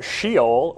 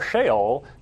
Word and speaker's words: Sheol: And so I might pronounce it Sheol or Sheol, depending on when you hear me say it. So you get to choose. I don't know. Is there a right way? – They're both fine.